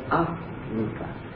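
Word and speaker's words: arūpa: Now, when we come to the formless jhanas, they're called Arupa. The uh, syllable A is non an atta. is me and an an not n like you would say it in English. An. Pali is pronounced like German.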